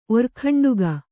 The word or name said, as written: ಒರ್ಖಂಡುಗ